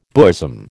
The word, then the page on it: bosom: American English